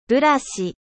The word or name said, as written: brosse